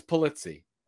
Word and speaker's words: Pulizzi: You know, you were close. It's Pulizzi. But Pulizzi, you were in the ballpark enough where I would not correct it. If you did the hard Uzi on the end, I probably would have to say, hey, you know, my ancestors are really upset about that.